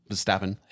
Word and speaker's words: Verstappen: Verstappen.